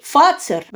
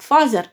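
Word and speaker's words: Fazer: И здесь Э, Стокман и Фацер, в России его произносят как Фазер, начали свои предприятия, которые до сих пор являются лидерами финляндской промышленности, а, э, в общем-то, по происхождению они были как раз петербуржцами.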